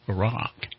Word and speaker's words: Iraq: But I can tell you that when we did go into Iraq...